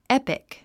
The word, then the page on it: epic: épik; エピク